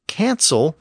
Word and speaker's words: cancel: And since cancel retains the hard K sound at the beginning, we know that that version of the word came in with the Normans.